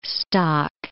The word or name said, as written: stock